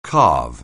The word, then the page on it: carve: anglais